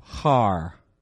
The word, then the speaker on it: Har: This is what the word looks like in the Hebrew, Har. is how it's pronounced, okay? This is the vowel point that tells us it's a short A.